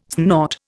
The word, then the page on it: snort: Indian